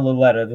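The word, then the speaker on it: Lauletta: How do you pronounce his name? – Kyle Laletta, the third string quarterback.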